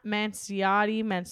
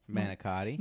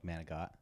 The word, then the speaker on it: Manicotti: So, it's not Manciotti, it's Manicotti. – Manicotti, yeah. How do you have... – Well, some people say like Manigot.